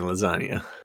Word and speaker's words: lasagna: It's just silent G in lasagna.